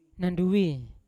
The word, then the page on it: nandui: Yawa